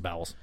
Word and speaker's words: bowels: Is this a key in the bowls kind of a situation? – Is that bowls? It's actually pronounced bowels. Sorry.